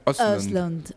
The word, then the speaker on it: Östlund: El director es Ruben Östlund, o cómo se pronuncia Östlund.